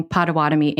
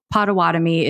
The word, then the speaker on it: Potawatomi: Before we get started, I just want to acknowledge that I appear to have been pronouncing Potawatomi incorrectly for the last couple of seasons, and I apologize. I grew up hearing it as Potawatomi, and for some reason, I changed it to Potawatomi. I imagine I did look into it and it wasn't just a decision that I made, but after some review, Potawatomi is the correct, even though anglicized, way to pronounce that name of that tribe.